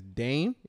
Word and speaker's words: Daym: The host is by, I think it's Dame. It's spelled D-A-Y-M.